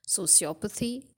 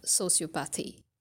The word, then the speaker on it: sociopathy: So for sociopaths, you can have two pronunciations. You have sociopathy and you have sociopathy, right? And for psychopaths, the condition is pronounced only one way, and that is psychopathy.